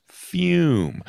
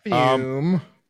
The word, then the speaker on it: FUM: It's spelled FUM, F-U-M, but it's pronounced Fume. – Fume.